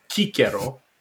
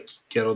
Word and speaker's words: Cicero: Uh, e vorba despre Marcus Tullius Cicero sau cum ne place să spunem când vrem să fim foarte, foarte, foarte corecți, Cicero, pentru că uh, așa se, așa se pronunța real. E mai aproape Cicero de Cicero.